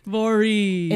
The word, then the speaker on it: Voree: Is that how you say it? Voree? – Voree.